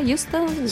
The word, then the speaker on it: Houston: dopo tu mi correggi sulla pronuncia, ACT World Fest in Florida, Houston? – Sì, perfetto, bravissimo.